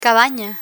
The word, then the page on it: cabana: spanish